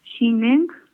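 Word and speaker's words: Zhineng: Eh, te lo voy a deletrear porque se escribe chin en pero es ZHI, práctica espacio luego ZHI. Sí. N E N G Shineng.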